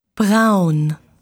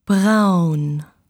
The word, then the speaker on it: Braun: Braun. – Brown. – Braun.